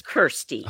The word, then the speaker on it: Kirstie: So tell us a little bit about, is it Kirsty or Christy? It looks like it's Kirsty. – Oh, it's spelled, am I crazy or is it spelled Christy? – No, it's spelled K-I-R-S-T-I-E, although I may have spelled it wrong in an email to you.